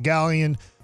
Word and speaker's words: Gallen: So I think, obviously, Zach Gallen. They had, however you say it, I always say it wrong. – Gallen's right. You did it right.